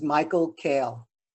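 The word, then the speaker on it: Michael Kail: My name is Michael Kale, K-A-I-L. – And Michael, M-I-C-H-A-E-L? – Correct.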